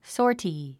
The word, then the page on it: sortie: ɔsɔ’ːrti; ソォティ